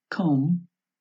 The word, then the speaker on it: comb: A veces la letra B es muda, lo que en inglés se llama silent B, y que ocurre en palabras que terminan en las letras MB. Entonces se pronuncia solamente la M y no la B final, como es el caso de las palabras lamb, cordero, comb, peineta, o climb, escalar.